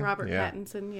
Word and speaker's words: Robert Pattinson: Now it's going to be Robert Pattinson. Is that how you say his name? – Robert Pattinson, yeah.